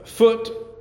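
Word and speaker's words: Phut: So, verse 6, "And the sons of Ham, Cush, and Mizraim, and Foot." Isn't that a name for your kid? Foot. But we didn't spell it F-O-O-T. It's P-H-U-T. Foot.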